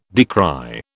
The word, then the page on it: decry: British English